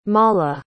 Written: mala

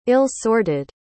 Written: ill-sorted

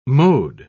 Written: mowed

